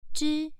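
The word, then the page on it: zhi: tone 1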